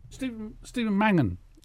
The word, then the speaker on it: Stephen Mangan: Stephen Mangan.